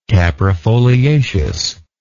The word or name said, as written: caprifoliaceous